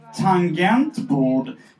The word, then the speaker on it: tangentbord: Jag är precis som Maria från Norrköping och uttalar G i tangentbord som ett J. Mina vänner från Göteborg har vid flertalet tillfällen påpekat detta och eh, när jag hörde senaste Arkivsamtal så ringde jag upp en barndomsvän från Norrköping och fick veta att hon uttalade G som ett J.